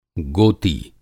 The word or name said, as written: गोती